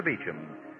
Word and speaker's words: Beauchamp: The main Dr. Beecham office is at 5th and Broadway in downtown Los Angeles, spelled B-E-A-U-C-H-A-M-P, pronounced Dr. Beecham.